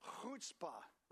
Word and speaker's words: chutzpah: It's spelled C-H-U-T-Z-P-A-H, chutzpah. Okay, so if you want to say Yiddish or Hebrew, you got to get the gutturally sound in your throat. So try to clear your throat with me right now, okay?